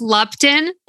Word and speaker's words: Lupton: It's pronounced Lupton. – Lupton. Okay. Do you get a lot of like different variations? – Oh, yeah. It's like Lumpkin.